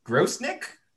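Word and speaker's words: Grosenick: So, Kings goalie Troy Grosnick? Is that how you say it? – I believe that's how you pronounce it, yeah.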